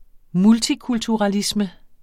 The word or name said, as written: multikulturalisme